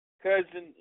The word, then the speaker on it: cozen: Cousin, it looks like cousin, C-O-Z-E-N, but the pronunciation is cousin, all right?